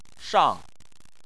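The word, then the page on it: shang: tone 4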